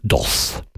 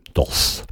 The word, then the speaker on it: Doce: Die nennt sich DOS. Geschrieben D-O-C-E. DOS.